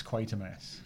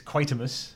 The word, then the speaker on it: Quatermass: is like how do you pronounce Quatermass? because the B, the original BBC serial seems to be very much Quatermass, and to Donlevy it's Quatermus, and then I think it's actually the actress playing Mrs. Carroon uh, who, oh, uh, Margia Dean.